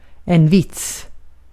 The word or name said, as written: fıkra